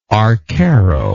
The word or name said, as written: arcaro